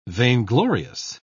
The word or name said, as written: vainglorious